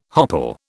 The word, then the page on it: hopple: British English